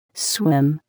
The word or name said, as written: swim